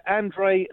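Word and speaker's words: André: His name's pronounced Andre. It's not Andre. Andrex. Andrex. Andrex the toilet roll. Yeah, Andre.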